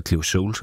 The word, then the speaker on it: Cleo Sol: Ezra Collective, Nubia Garcia, Brandy Younger, Coco Roco, Emma Jean Thackeray, altså Cleo Sol, for den sags skyld, eller Cleo Sol, som det er udtalt,